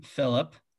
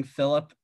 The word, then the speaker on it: Philip: I don't like pronouncing Philip. I think it's hard. You like kind of swallow the second syllable if you're not saying it like you're in the crown, like Philip.